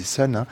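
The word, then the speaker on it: Sunn: Ça s'écrit Sun Home, on dit Sun, c'est le groupe de Stéphane O'Malley.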